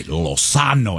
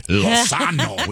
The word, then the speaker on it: Lozano: He'd be like, if you ever say our name on the radio, you have to say it right. Lozano. And yes, he does this thing with his hand. Lozano with his hand.